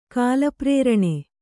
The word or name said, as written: ಕಾಲಪ್ರೇರಣೆ